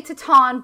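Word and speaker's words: Titane: I want to say Titan, but Julia Ducournau said Titan. So I'm going to say that she is right. That's how we're going to be pronouncing it.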